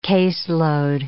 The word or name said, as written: caseload